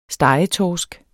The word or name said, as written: stegetorsk